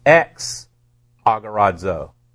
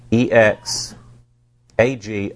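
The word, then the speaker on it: exagorazo: So it's pronounced X agorazo. EX agorazo. X agorazo.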